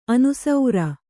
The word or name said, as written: ಅನುಸೌರ